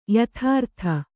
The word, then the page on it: ಯಥಾರೀತಿ: yathārīti